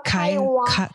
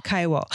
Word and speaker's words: Kaiwo: mal, ähm, du sprichst ein super Englisch und in diesem Film sprichst du ja auch die Sprache der Kaiwo, richtig ausgesprochen? Genau, Kaiwo. Kaiwo.